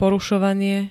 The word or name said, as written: porusovanie